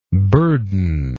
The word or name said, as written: burdenless